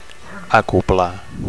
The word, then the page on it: acoblar: Catalan